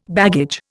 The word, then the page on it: baggage: Indian